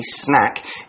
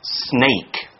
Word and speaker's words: snack: Yeah, a snack, S-N-A-C-K, snack, is a small amount of food eaten between meals. Now it could be fruit, a cookie, some chips, almost anything. But be careful to pronounce it snack, not snake.